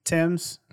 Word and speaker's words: Thames: They left them along a river, the River Thames. – Thames. – Oh, sorry. However those people talk is just. – Yeah, that's how they say it over there, I think. Them.